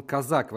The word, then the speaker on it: Козак: Я вот под завершение еще про Козака, но он казак вообще, я посмотрел в Википедии, его Дмитрий Казак, он на самом деле, у него ударение, он раньше был, кажется, Козаком, потом как бы ударение переползло на другую, стал казаком.